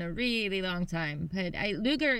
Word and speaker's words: Lueger: So it's not Lueger, it's Lueger. – Lueger. I think it would be Lueger because it's the UE thing.